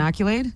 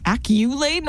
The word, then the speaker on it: accolade: Giving someone an accolade? – Acculade? No, I think you're saying it wrong. No. Accolade.